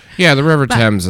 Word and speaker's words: Thames: The Thames. Thank you. It's T-H-A-M-S, and I was like, I don't know, that's not how it's pronounced, but the Thames.